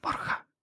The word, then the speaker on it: Borja: Eh, a mí me llamaban Boga o Boya. Eh, Borja. – Pero hoy... Borja.